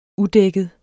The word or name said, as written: udækket